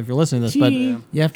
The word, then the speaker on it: Cheney: The town where the actual campus is located is so spelled Cheney, which how would you pronounce that? – Cheney? – Yeah. It's pronounced Cheney.